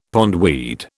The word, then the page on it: pondweed: British English